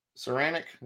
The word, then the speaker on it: Saranac: But this thing is by Saranic, did I say that right? – I believe so.